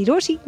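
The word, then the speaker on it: Rochie: मेरा नाम है मिर्ची रोशी, नाम की स्पेलिंग है RO CHIE, and while you do that कहीं मत जाना जाना क्योंकि वापस तो यही है आना.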